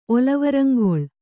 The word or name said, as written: ಒಲವರಂಗೊಳ್